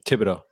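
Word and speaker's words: Thibodeaux: Thibodeau.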